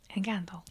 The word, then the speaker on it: Encanto: Nous parlerons donc Enkento. c'est comme ça que ça se prononce Encanto. – Encanto.